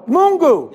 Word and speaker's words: Mungu: And he will always be Mwana wa Miyungu. Oh, I said it wrong? Mwana wa Miyungu? Oh, Mungu.